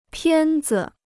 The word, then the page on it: 片子: piàn zi